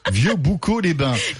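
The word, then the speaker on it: Vieux-Boucau-les-Bains: Oui, c'est euh, il a lieu euh, à, à, Vieux Beaucoup les Bains. – Vieux Boucaux les Bains.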